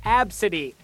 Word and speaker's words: Abcde: The kid's name is spelled A-B-C-D-E and pronounced Absidy.